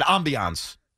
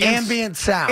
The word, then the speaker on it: ambient: I said ambiance. – No, ambience. Ambient sound.